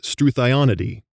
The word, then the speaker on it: Struthionidae: The name of the ostrich family is Struthionidae. Some might pronounce this word Struthionidae, so thigh instead of thee.